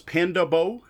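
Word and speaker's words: Pandabo: The way I think this is pronounced is Panda Bo. It's all one word. It's Panda B-O, not as in body odor.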